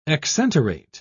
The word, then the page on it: exenterate: British English